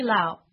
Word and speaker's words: Liao: My name is Judy Lau. That's spelled L-I-A-O.